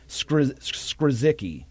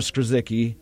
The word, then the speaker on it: Skrzycki: I'm going to pronounce this, the Polish name, Skrzycki. So it's spelled S-K-R-Z-Y-C-K-I, Skrzycki. I hope I'm pronouncing that right.